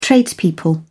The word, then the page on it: tradespeople: British English